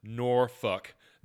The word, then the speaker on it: Norfolk: That has on the steps leading up to the stage, it's pronounced Norfolk, not Norfolk.